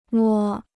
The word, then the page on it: 摸: mō